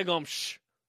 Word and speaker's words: Gomes: The, I don't know, the Portuguese pronunciation. I speak Portuguese. – Yes. – It's Nadia Gomes.